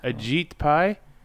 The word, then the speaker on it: Ajit Pai: Okay, the number that I found is for the head of the FCC, Ajay, how do you say his name? – Say Ajit. – Ajit Pai? – Yes.